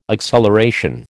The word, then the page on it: acceleration: American English